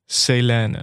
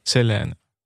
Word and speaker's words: Selene: En dat heb ik vorige week heb ik haar naam verkeerd uitgesproken. Nadat ik het eerder al een keer goed had gedaan. Eh, maar het is dus Selene. Oké. Okay. Selene.